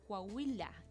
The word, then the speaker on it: Coahuila: que de hecho fue la semana pasada en Coahuila, México.